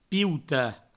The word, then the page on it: piutar: pieuta